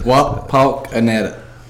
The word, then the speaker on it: Wapakoneta: Wapakoneta. – Wapakoneta. – It sounds like Chuckawinity in North Carolina almost.